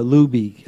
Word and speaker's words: Lubee: And what is the Lubee come from? – That was our nickname of our founder. His name was Louis B., so they just called him Lubee.